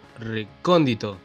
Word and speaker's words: Recóndito: ¿Cómo se pronuncia así? ¿Recóndito? ¿Recóndito? Recóndito. El logo, viste, que tiene como una O atravesada. Eso sí. Eh, por eso pusimos el logo así, por el acento, yo.